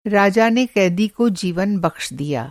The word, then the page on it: बख़्शना: بخشنا